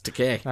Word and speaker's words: Takei: Takei rhymes with gay. That's what he says. – Okay. – Yep. – It's not Takei? – Nope. Right. It's Takei.